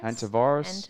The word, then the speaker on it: hantavirus: Then the third one on that shit list was the Hantavirus virus. I think it's how you say it. – Hantavirus.